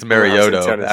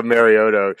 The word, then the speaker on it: Mariota: The Titans fans actually corrected me the other day. Like, I think it's Mariota actually per Goodell. – Yeah, Mariota. Yeah, I forgot they butchered his name.